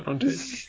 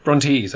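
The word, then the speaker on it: Brontës: Brontes. Brontes, I should say. – Oh. – Brontes. Yeah. I always pronounce it wrong.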